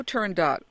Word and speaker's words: Turandot: Turandot. – Turandot, yeah. – Okay. – Try this one. Colored, C-O-L-O-R-E-D, plus Q.